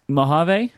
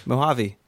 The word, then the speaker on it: Mojave: Was it Mojave? No. – Oh gosh, It's a long E, Mojave.